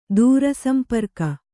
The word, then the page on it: ದೂರಸಂಪರ್ಕ: dūra samparka